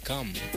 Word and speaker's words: Cham: Cam, ça s'écrit C-H-A-M, ça s'écrit Cham, mais ça se lit Cam.